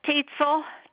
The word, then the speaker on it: Tietzel: Phyllis Tietzel, T-I-E-T-Z-E-L.